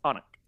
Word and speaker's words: Alnwick: If you go to a certain place in England, you might think A-L-N-W-I-C-K. How do you say that? Alnwick? Yeah. Nope, that's wrong. It's Onik.